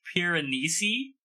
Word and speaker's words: Piranesi: Is, I think it's Piranesi, how you say it? – It's how it's pronounced.